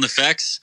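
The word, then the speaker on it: NEFFEX: It's kind of like an introduction, I guess, for the people that don't know about the group, but, it's called Nefex, right? – Yep. It's called Nefex. Most people say it wrong.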